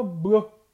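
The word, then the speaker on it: bleue: O azul tem o bleu, que é o masculino, e o feminino também se fala blu, mas eles acrescentam um E no final, mas a pronúncia é a mesma, tá? Então existem dois azuis, porém o azul feminino vai um E no final, mas não há mudança de pronúncia, seria blu, blu, tá?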